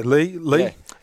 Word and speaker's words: Leigh: Am I pronouncing that correctly? – Yeah, Lee. L-E-I-G-H.